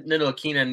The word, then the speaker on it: Ntilikina: I think Fred Ntilikina needs just the right fit at this point.